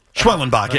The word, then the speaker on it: Schwellenbach: Schwellenbach, yes.